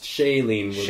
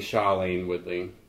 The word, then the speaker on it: Shailene Woodley: How is it pronounced? – Shailene Woodley. – Shailene? – Mm-hmm. – How have we been pronouncing it? – I've been saying Shailene Woodley.